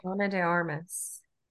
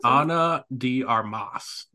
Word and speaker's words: Ana de Armas: I think it's Ana de Armas. – Ana She's from Cuba, I think. So. – Ana de Armas.